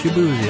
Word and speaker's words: Shaboozy: Yes, Shaboozy. That's the right way to say it.